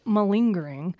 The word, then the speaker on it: malingering: This is how you actually pronounce it, malingering.